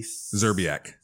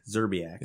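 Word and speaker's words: Szczerbiak: So in 2005, the Coyote was mocking, I don't know how to say this guy's name, Wally Zerbiak. – Zerbiak.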